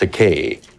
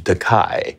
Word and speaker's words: Takei: He called me Takei, but I corrected that. I said, my name is pronounced Takei, but I don't object to Takai, because that's a Japanese word that means expensive.